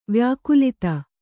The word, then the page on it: ವ್ಯಾಕುಲಿತೆ: kannada